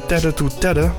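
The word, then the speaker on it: Tete2Tete: En ook op 19 september Plot Twist bij Tedder to Tedder. Zo schrijf je dat niet, maar zo spreek je dat misschien wel uit. Het is T-E-T-E en dan het cijfer 2 en dan T-E-T-E.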